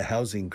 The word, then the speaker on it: housing: And probably the biggest problem is the housing crisis. Crisis de la vivienda. Housing. Fijaos cómo se pronuncia. Housing. La S de house. House is casa. Hogar. House. La S es una S fuerte.